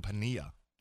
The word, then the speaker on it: Piniella: It's Lou Piniella. – Oh, it is? – But everybody, yeah. – Oh, there's no, the L is silent. – No, the L is a Y. Double L in Spanish is Y.